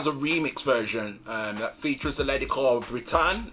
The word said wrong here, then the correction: Brittany: Uh, have I, have I um, pronounced that correctly? – It's actually Brittany.